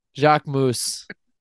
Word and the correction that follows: Jacquemus: It's pronounced Jacquemus.